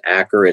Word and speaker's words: Achor: Author is Sean, I believe his last name is pronounced Acher. It's spelled A-C-H-O-R, but The Happiness Advantage.